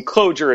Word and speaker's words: Clojure: It's like, oh, I mean, Clojure itself, the name is not necessarily the best either, which is why I pronounce it with a very hard J, because otherwise life gets really difficult really quickly.